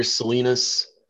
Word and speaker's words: Salinas: So on social media, on Twitter, I'm at author Salinas. It's spelled, you know, just my regular last name, author, and then S-A-L-I-N-A-S.